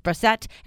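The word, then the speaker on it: Brossette: That's B-R-O-S-S-E-T-T-E performance. Brosette.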